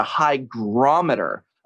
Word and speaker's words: hygrometer: It's not a hydrometer. It's a hygrometer, apparently, is how it's pronounced. That's what people said.